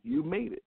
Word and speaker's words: You Made It: Am I pronouncing that right? You Made Late? How do you pronounce it? You Mad Late? – No. You made it.